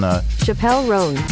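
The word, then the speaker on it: Chappell Roan: All right, so I got the pronunciation right on Chappelle Roan. – Yeah, Chappelle Roan. Did I say Chappelle or Chapel? I think I said Chappelle. – Chappelle Roan.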